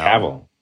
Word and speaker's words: Cavill: And then do you want to, could you mind if I come back and do some skydiving with you and Henry Cavill? It's, how do you say his last name? Cavill? Cavill? Cavill. Cavill, yeah.